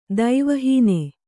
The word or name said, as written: ದೈವಹೀನ